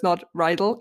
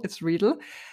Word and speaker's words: Riedel: So, okay, first, my name is Anna Riedel. Many people pronounce it wrongly because it's a German way, German word. So it's not Riedel, it's Riedel.